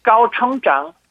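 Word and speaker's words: 까오청장 (高成长): 한자를 그대로 고성장 그대로 써갖고 발음은 고는 까오, 성장은 청장, 청장. 그래서 고성장 하면은 까오청장, 까오청장입니다.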